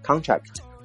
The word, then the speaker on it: contract: OK，那么有一个短语呢，叫做 aleatory contract， aleatory contract 啊， contract 拼成 c o n t r。 A-C-T 这个词啊，作为名词读作 contract，大家注意一下啊，重音在前面，叫做合同的意思。